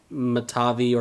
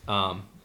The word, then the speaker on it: Mitavi: So the way I've been pronouncing it is like Mitavi or Mitavi. It's spelled M-I-T-A-V-I.